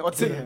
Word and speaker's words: Özil: Tapi kalau orang yang baru banget belajar Jerman, baru ke Gutha tuh bacanya Özil. Pakai C. – Tapi bener, jangan bacanya C coy. Özil bacanya, bukan Özil. – Iya, tapi bukan C yang kayak C Indo. – Bukan C Indo, bukan. Dia TC deh, Özil.